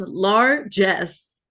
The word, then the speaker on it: largesse: Today's word to your mother is largesse, pronounced largesse.